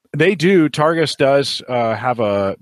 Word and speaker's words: Targus: So, Brian had said in the chat, he had said he loves his Targus. Is that how you pronounce Targus.